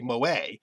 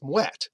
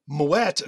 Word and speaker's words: Moët: So the name, the temptation is to pronounce it as you think French is pronounced. That would be moe. But in this case, the name is Dutch, so it's moet.